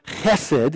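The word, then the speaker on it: chesed (חֶסֶד): One person defined this Hebrew word that is pronounced chesed, and you got to have that ch sound, that chesed.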